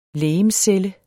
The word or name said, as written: legemscelle